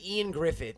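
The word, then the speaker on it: Ioan Gruffudd: I think it's actually Ian Griffith is how he pronounced. Is how we're supposed to pronounce it.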